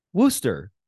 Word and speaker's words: Worcester: because it's spelled W-O-R-C-E-S-T-E-R, but pronounced Worcester. – And if you're a Bostonian, it's Worcester.